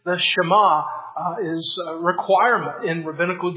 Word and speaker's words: Shema: Some people may look at it and pronounce it Shema. It's actually the first Hebrew word of verse 4, and that's how it gets its title. Shema, Shema Yisrael, Adonai Eloheinu, Adonai Echad.